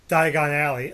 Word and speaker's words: Diagon Alley: Before they go to Diagon Alley.